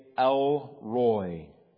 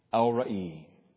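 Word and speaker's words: El Ra'i (אל ראי): In the English, we would say El Roy, E-L space R-O-I. In the Hebrew, it's pronounced El Ra'i.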